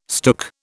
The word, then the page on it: stook: British English